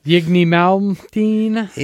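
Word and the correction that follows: Yngwie Malmsteen: Inge Malmsteen. – I never knew how to say his name. – Inge Malmsteen. Inge.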